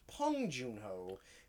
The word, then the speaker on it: Bong Joon-ho: Although apparently it's pronounced Bong Joon-ho. Because of the way Korean to English is. – Yeah, because Spike Lee kept saying Pong at the Oscars.